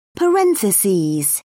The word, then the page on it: parentheses: British English